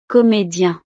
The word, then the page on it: comedien: frances